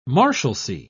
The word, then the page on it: marshalcy: British English